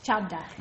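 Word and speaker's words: Chavda: I recommend it by Mahesh Shabad. I don't know if you've, how do you say his name? – Mahesh Chadda. – Mahesh Chadda.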